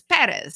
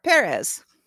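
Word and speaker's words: Pérez: And there's an accent on the first E. When you see the accent, that's where you put the emphasis, Perez. – That's what it's there for, Perez.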